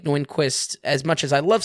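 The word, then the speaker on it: Nyqvist: I think I pronounced that right. Michael Nyquist? – Yeah, go for it.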